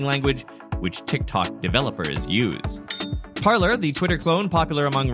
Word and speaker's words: Parler: And side note, I know Canadians, their brand name should be pronounced Parler, since it's obviously named after the French word for speak, but let's let them have their fun.